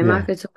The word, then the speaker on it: Makaton: Yeah, so Makaton is it's a form of sign language used for communication, but it doesn't have every single word like British sign language would.